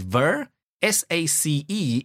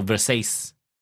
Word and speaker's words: Versace: V-E-R, S-A and C-E. You wouldn't break it in the middle there. S-A-C-E is Sace. So Versace or Versace.